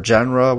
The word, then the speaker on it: genre: Uh, we'll talk about what I want to do right now is talk about a, uh, a jar or a genre, whatever you. How do you say that damn word? – It's a genre. A genre.